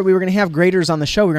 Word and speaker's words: Graeter's: So you can do a search for Grater's Ice Cream. But it's spelled differently than we would expect. – Yes, G-R-A-E-T-E-R-S. Okay, G-R-A-E-T-E-R-S.